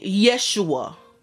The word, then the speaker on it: Yeshua: So basically, Yeshua was the pronunciation of the name in Hebrew. But being that Greeks did not have an alphabet that made the sh sound, when they made reference to Jesus, they chose a letter.